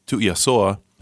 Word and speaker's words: Tuiasoa: And my name is pronounced exactly how it's spelled, Tuiasoa.